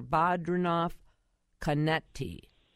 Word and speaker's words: Badrinath Konety: And we are so happy to have with us on our John Schuster Coldwell Banker hotline, Dr. Badrinath Konety.